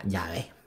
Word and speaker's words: Yahvé: Y el, pues eso con, con el sonido vocal, como lo pronuncian los judíos, se pronuncia Yahvé, ¿vale?